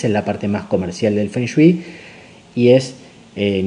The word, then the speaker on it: Feng Shui: que se pronuncia en chino Feng Shui,